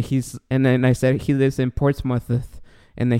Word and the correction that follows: Portsmouth: he's like, "No, no, no, that's not how you say it. You say Portsmouth," and I'm I'm still saying it bad, but he corrected me like you guys do all the time.